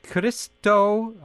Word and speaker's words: Christo: Specifically, first-time winner, it's Christo, he says, pronounce the K, Christo, I'll just say Christo Stavrev, who is in Bulgaria.